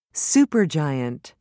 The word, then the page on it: supergiant: British English